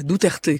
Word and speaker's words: Duterte: Un étonnant discours de lucidité et de vérité de la part de ce président philippin, Rodrigo Duterte. – Voilà, Duterte, que je puisse le prononcer au moins une fois correctement.